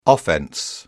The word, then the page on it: offense: British English